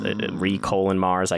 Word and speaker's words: re:MARS: I think that's Re:Mars. I guess it's just pronounced ReMars.